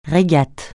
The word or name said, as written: régate